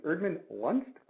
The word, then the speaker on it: Erdman-Luntz: I have Laura, and her last name is Erdman Lunst.